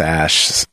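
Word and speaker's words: Æ: But like the A and the weird E is pronounced Ash.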